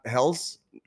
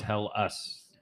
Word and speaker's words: Helles: Is that not Helles? Helles? – No, well, when Brian looked it up online, it's pronounced Hell Us. – Like Hell Us, yeah.